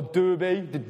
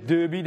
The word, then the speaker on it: Derbe: And we're going to go now to Iconium and Lystra and a place called Derbe, not the one in the Midlands, a different, or Derbe, Derbe, depending on how you say it. It's Derbe with an E on the end.